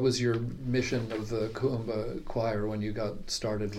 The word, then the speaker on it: Kuumba: Because I'm Kuumba. – Kuumba. – Yeah. So it's KU UMBA.